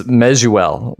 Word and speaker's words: Mezeul: So it's close. It's Mezeul. So Mezeul.